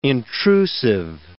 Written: intrusive

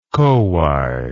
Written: kowhai

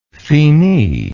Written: fini

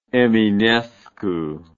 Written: eminescu

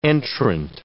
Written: entrant

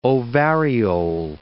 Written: ovariole

